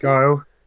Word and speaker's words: Guile: Hopefully, is it Guile? Is that how you pronounce it? – Guile. Oh, yeah, it goes with everything.